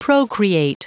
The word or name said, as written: procreate